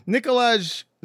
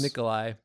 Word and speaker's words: Nikolaj: I think it's Nikolai, but yeah. – Oh, why is there a J, though? – That's because that's how you pronounce the J in some of those Scandinavian weird Slavic languages.